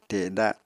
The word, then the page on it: teda: apatani